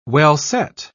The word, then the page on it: well-set: British English